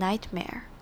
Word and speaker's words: nightmare: 那 nightmare 的这个中间的 T 也是轻轻带过就好，不用念得很明显，而是轻轻带过念成 nightmare。